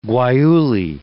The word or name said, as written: guayule